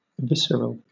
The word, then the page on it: visceral: British English